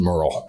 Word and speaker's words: Merrill: And even up in Merrill and parts of Lincoln County. – Well, you pronounced that wrong. It's actually pronounced Merle.